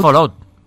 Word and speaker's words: Fallout: Fallout. ¿Y qué es Fallout? Que es, no es Fallout, lo leemos mal, realmente se pronunciaría Fallout.